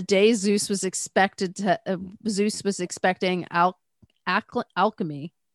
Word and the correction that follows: Alcmene: Why can't I say her name? Alcmene.